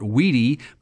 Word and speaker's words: WEDI: It's the Work Group for Electronic Data Exchange, and most folks call it WEDI, but it's spelled W-E-D-I.